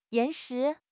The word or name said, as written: 岩石